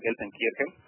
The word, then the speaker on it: Gelsenkirchen: Bien, bueno, para terminar, contame algo de Gelsenkirchen, no sé cómo está mi alemán, pero la ciudad donde está instalado este estadio de Schalke, porque no es muy conocida. – Sí, te cuento muy bien pronunciado, Gelsenkirchen.